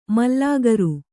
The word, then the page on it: ಮಾಲಗಾರ: kannada